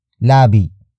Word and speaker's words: labi: The next word is labi. Labi. Labi. Labi is spelled as L A B I.